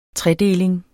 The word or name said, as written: tredeling